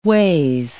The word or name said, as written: ways